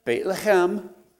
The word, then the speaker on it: Bethlehem: And the answer is in, pronounce it correctly, Bethlehem. You got to clear your throat if you want to pronounce Hebrew or German.